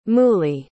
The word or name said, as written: moolie